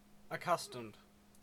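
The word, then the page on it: accustomed: Canadian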